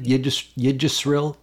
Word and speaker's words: Yggdrasil: Yggdrasil.